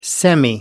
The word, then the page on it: semi: American English